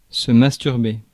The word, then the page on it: masturber: France; /mas.tyʁ.be/